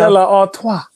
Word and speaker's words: Stella Artois: I got some Stella Artois, whatever the how you pronounce Stella Artois.